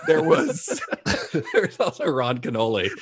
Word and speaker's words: Kenoly: Ron Canole? – That is. It's spelled K-E-N-O-L-Y. – Okay. – So it's like kid like... and the back part of holy.